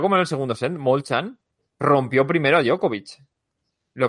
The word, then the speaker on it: Molchan: Djokovic se llevó su Belgrado 2 ganando a Alexander Molchan, que se pronuncia así, Molchan, es rollo como el sexto, un esloveno, 6-4, 6-3, en un partido...